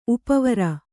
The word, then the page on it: ಉಪವರ: upa vara